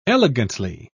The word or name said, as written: elegantly